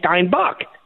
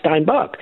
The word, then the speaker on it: Steinbuch: I say Steinbuch. Well, you know then my name is pronounced Steinbuch.